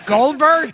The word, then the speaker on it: Goldberg: I still haven't heard you say my last name. – Yes. – Yes. – Goldberg? – That's it. Yeah, that's it. – Okay. – That's Goldberg. – Yeah. That's the Italian pronunciation.